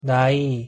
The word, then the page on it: 나이: Korean